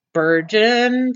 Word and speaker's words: Spurgeon: Both Jessa and baby are healthy, and the little girl named Ivy Jane now joins Big Brothers Spurgeon 3 and Henry 2. Okay, now I'm going to barf, because Spurgeon is really a terrible name. – What does it even mean? – Well, it rhymes with sturgeon.